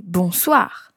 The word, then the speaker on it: bonsoir: Good evening, c'est bonsoir. – So it's that same sound again. Bon, and then it's followed by soir this time. Listen again. – Bonsoir.